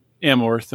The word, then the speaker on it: Amorth: So let's start out with who this individual is, Father Gabriel Amorth. Am I saying that correctly? – Yeah, that's right. That's right.